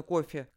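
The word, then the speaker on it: Coffey: Люди часто неплохие, век за веком распинающие Христа, даже даже ежедневно распинающий, если отсылать к словам Джона Кофи, как кофе, только пишется по-другому, да?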